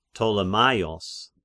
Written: Ptolemaios

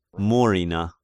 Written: Murina

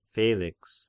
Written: Felix

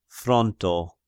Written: Fronto

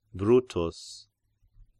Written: Brutus

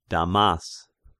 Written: Damas

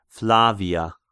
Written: Flavia

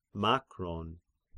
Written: Makron

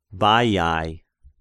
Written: Baiae

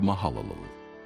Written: Mahalalel

